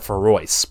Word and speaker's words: Feroz: It's spelled like Feroz, but I remembered that they said it Feroz, but okay.